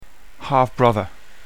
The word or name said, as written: half brother